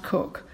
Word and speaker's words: Coke: It's spelled Coke, but it's pronounced Cook.